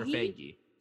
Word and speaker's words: Feige: Is it Feige or Feige? – Feige.